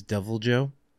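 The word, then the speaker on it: Deviljho: His name is Devil Joe. The H is silent. Devil Joe. Where's the H? – In Joe. J-H-O.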